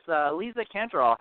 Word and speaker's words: Lisa Cantoral: Yep, and we're also with Lisa Cantoral.